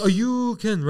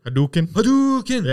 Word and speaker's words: Hadouken: He's the one that goes, Ayuken, right? – Hadouken.